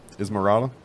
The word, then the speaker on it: Esmeralda: Am I saying that right? Esmeralda.